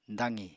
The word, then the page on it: daqi: apatani